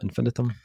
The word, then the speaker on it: Ad Infinitum: The last track, this 1977 Ad Infinitum. Is that how you pronounce that? Infinitum.